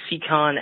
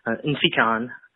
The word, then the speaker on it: Nsikan: It's spelled N-S-I-K-A-N. Last name A-K, like in Paul, A-N. My parents pronounce my first name with the N, so it's Insikon.